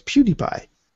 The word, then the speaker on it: PewDiePie: It's PewDiePie. That's how you say it.